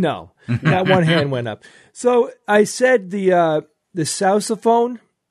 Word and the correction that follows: sousaphone: because, you know, it's spelled S-O-U-S-A, and that's basically, yeah. – It's sousaphone. – It's sousaphone. – Yes.